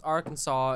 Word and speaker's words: Arkansas: It's Arkansas. – It is spelled Arkansas, but the reason it's pronounced Arkansas is because of it's the South, and South are stupid.